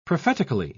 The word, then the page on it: prophetically: British English